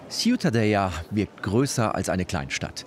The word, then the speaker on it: Ciutadella: Aber Ciutadella, richtig ausgesprochen? – Ja, das Städtchen, Ciutadella. – Ja, ist schon besonders schön. – Ciutadella, ja.